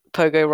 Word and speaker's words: POGO: And whenever we talk about our logo, it always makes people chuckle that POGO rhymes with logo.